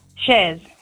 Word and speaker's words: Chers: Shers or Shers? – Shers. – Shers, because the girls here have written your name as C-H-E-R-S. Is that how you spell That's it? – That's how you spell it.